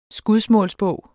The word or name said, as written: skudsmålsbog